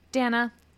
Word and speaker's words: Dana: Dana. Thank you. They put that in the Patreon description. Dana, you're a star. We love you so much. You are such a star. Your name rhymes with banana.